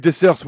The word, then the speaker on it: Dessers: Cyriel Dessers, je sais pas comment ça se prononce, pardon. – Ah, oui, Dessers, oui, le, oui le, meilleur buteur du Nord, oui.